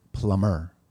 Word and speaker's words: plumber: Why is plumber pronounced plumber without the B?